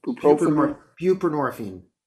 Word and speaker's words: buprenorphine: And I think We need the buprenorphine. How do you say that? – Buprenorphine.